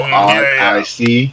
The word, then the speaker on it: Odyssee: Any listeners out there, it's spelled Odyssee. So Odyssey.